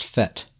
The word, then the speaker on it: fete: We were annoying him with the last episode because we kept calling this set Death Dealer's Feet. And apparently this word, F-E-T-E, it's a French word, according to Grizzly, and it's pronounced fet, right? Isn't that right, fet? – Yeah, it's fet.